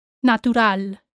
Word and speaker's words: natural: Natural, natural. See how this T is just like any other Spanish tea. Natural. Natural. And the stress falls on the A. Natural.